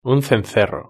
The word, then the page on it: un cencerro: Spain Spanish